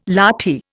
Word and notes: lathi: Indian

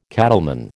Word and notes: cattleman: American English

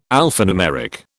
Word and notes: alphanumeric: British English

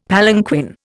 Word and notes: palanquin: Indian

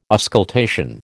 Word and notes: auscultation: American English

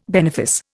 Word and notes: benefice: Indian

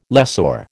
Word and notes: lessor: American English

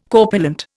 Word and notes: corpulent: Indian

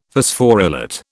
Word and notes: Phosphorylate: British English

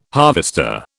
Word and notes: harvester: British English

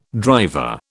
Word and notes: driver: British English

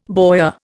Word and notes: boyar: Indian

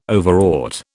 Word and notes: Overwrought: British English

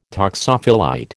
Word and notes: toxophilite: American English

